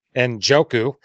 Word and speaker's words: Njoku: Tua, CMC, Pollard, Cup, Waddle, and Juco. I said that wrong. – Njoku.